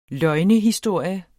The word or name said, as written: løgnehistorie